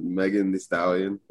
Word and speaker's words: Megan Thee Stallion: You said Megan Thee Stallion. – Is that not how you say it? – No, I'm just making sure.